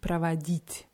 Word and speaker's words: проводить: Und nur bei der Grundform ist die Betonung auf dem i, pravadit.